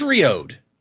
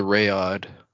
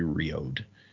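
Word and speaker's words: Reod: I think I always said the Rayod. – Yeah, I think we said Rayod or Red or something when we said it, but I believe the correct pronunciation would be Riode.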